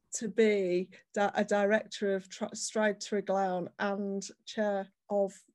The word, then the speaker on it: Stride Treglown: So not only are you now national chair of Women in Property, but you're also a director of Stride Treglown. I did pronounce that right, didn't I? – Yes.